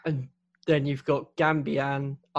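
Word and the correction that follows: Gbamin: I may have pronounced that incorrectly. – Jibamin. – Yeah, you've got Jibamin.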